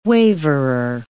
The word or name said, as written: waverer